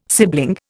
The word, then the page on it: sibling: Indian